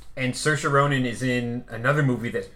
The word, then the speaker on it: Saoirse: Based on the Chekhov play with a crazy all-star cast, including Saoirse Ronan, who I've learned to pronounce her name right. – Yes, you nailed it.